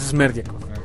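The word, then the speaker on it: Smerdyakov: ¿Es Smerdiakov? – Ya me puse, la última vez que nos vimos pronunciar el nombre, me puse a buscarlo, es Smerdiakov. Sí, el hijo de Kraven.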